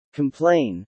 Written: complain

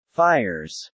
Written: fires

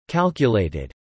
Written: calculated

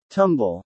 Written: tumble

